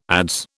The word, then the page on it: adze: British English